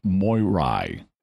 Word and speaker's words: Moirai: Yeah, so if that name is familiar to anybody, Atropos is from Greek mythology, and so are the Moirai, as you would more properly pronounce it.